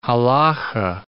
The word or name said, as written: halacha